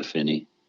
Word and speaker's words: Efini: A Finney.